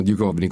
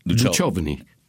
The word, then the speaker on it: Duchovny: Però alcuni americani dicono Duchovny col K. Altri dicono Duchovny.